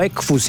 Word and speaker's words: Equus: Czy chodzi w takim razie o Hyundai Equus, albo Equus, jak niektórzy wymawiają, bo to po łacinie znaczy konie, jak przypomina Robert, a Tomasz Radomia też twierdzi, że chodzi o tego Hyundai.